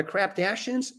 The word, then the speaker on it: Kardashians: And creeping up on the Kardashians is no longer on anybody's.